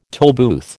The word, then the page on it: tollbooth: American English